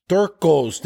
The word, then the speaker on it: Torkos: It's Torkos.